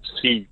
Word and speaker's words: SEAD: Seed, SEAD. – Seed as in like a seed, but SEAD. – Yeah, seed as in with D.